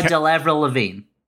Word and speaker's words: Delevingne: Do you think the casting was like, my guess is that they cast Cara Delevingne, or how do you pronounce her name? – I've got no idea. – It's Cara Delevingne.